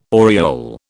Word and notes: oriole: British English

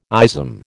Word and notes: ism: American English

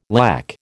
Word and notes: lakh: American English

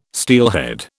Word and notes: steelhead: British English

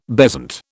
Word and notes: bezant: British English

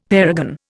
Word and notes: parergon: Indian

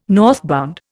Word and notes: northbound: Indian

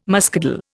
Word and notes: muscadel: Indian

